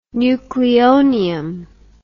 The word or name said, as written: nucleonium